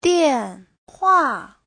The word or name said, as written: dian4hua4